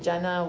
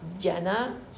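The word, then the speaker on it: jhāna: Jhana, qui peut se prononcer jhana ou dhyana, ça dépend si c'est du sanskrit ou du pali, et qui veut dire généralement état méditatif ou méditation.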